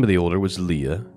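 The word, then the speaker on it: Leah (לאה): Leah's name, it's spelled Lamed Aleph He, and it is pronounced Leah. Leah, so very, very close to the English.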